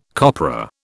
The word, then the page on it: copra: British English